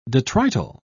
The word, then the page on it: detrital: British English